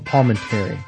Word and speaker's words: Palminteri: Palminteri.